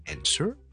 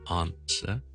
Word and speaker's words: answer: 你听到有三个地方分别嘅，听一次，美式系 answer。英式是 answer，第一个分别呢是第一个响音呢，英式英文是 answer，美式英文是 n 咁样好似 n n n， OK，呢个第一个分别。